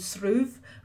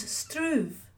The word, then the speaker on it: Shruve: You may have heard of Shruve being pronounced with a T, as Struve, but this consonant isn't written in the Irish form of the name, though it's often pronounced in northern dialects.